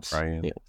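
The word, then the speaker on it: Trian: Also, during this time period while we were away on our hiatus, Disney successfully defeated Trion, or I guess how you say it, Trion.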